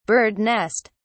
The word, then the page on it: bird nest: British English